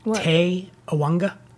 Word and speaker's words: Te Awanga: Te Awanga? It's T E A W A N G A. – That's what I believe. – Is that what you'd say? – Yes.